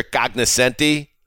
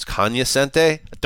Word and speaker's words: cognoscenti: Yes, the pop culture cognoscenti. Matt Money Smith told me that the G is silent. It's cognoscente. Don't care. I like cognoscenti better.